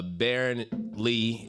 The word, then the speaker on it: Berran Lee: Yeah, Berran Lee is pretty much my whole handle. First and middle name is pretty simple. B-E-R-R.